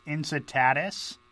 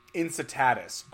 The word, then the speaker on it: Incitatus: his his name is pronounced Incitatus. – Incitatus. – Okay. – Yeah. Thanks. Incitatus.